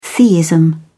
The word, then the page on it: theism: British English